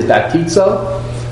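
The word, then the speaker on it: baptizo (βαπτίζω): When we try to pronounce that term, it typically is baptizo.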